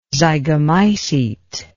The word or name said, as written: zygomycete